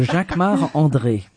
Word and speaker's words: Jacquemart-André: Jacquemart-André. Jacques. Or no, you say it like André. A Frenchman. – Jacques-Marc of course, because I'm French. So Jacques-Marc André.